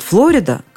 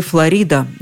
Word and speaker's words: Флорида: Действительно, мы всегда говорили «Флорида», даже не зная толком, где эта Флорида находится. Более того, это было зафиксировано в словарях, которые рекомендовали именно такое ударение. Хотя в словаре ударения Агейнка и Зарвы рядом с этим привычным вариантом появился в скобочках и другой – в английском «Флорида». Но эта хитрость не спасает.